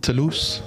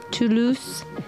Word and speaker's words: Toulouse: Toulouse. – Yeah, you want to know? Toulouse.